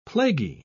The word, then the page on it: plaguy: British English